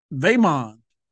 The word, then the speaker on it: Vaemond: When Vaemond, I'm saying his name like that. – You said Daemon. – Because his name rhymes with Draymond.